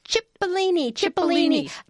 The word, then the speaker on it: cipollini: Cipollini.